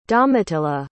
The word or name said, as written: domitilla